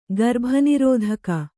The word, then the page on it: ಗರ್ಭನಿರೋಧಕ: garbha nirōdhaka